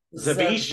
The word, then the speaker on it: Zawisza: So I went from Chapman to Zawisza. – Zawisza. – So that is how it's said.